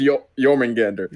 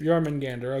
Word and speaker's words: Jormungandr: Was it Jormungandr? – It was Jormungandr. – Jormungandr, okay.